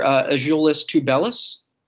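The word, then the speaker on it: Azuolas Tubelis: And I can't tell me how to pronounce the two-way guy's name, because I don't know how to. Can you tell me? – Sure. Azulis Tubelis. – Ajulis Tubelis. Okay, thank you.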